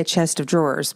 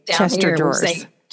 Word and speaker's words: chest of drawers: I'd say a chest of drawers, but you guys say Chester drawers.